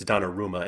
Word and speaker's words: Donnarumma: I'm just saying, and somebody in chat was just reminding us that it's Donnarumma, and you're absolutely right.